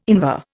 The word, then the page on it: Invar: Indian